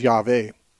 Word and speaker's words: Yahweh: Of course, this is a mispronunciation. The word Jehovah is an English word. Hebrew words don't have J's. There's no J sound in Hebrew. So a more authentic way of saying Jehovah is Yahweh.